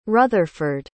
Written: rutherford

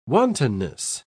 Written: wantonness

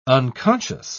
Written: unconscious

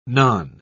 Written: non

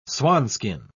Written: swanskin

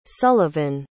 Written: sullivan

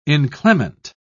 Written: inclement

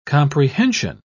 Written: comprehension

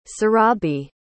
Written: surabhi

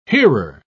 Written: hearer

